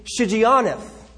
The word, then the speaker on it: Shigionoth: It's actually pronounced according to Shigionoth.